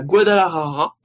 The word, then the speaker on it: Guadalajara: Le Mexicain est né à Guadalajara, Bravo.